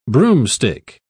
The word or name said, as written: broomstick